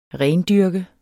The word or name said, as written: rendyrke